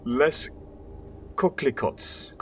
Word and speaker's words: Les Coquelicots: a group called Les Coquelles the Clots. I really fucked that up. I'll message you it. – Nice, thanks. – Les Coquelicots. Coquelicots. It's, yeah. That's pronunciation for you.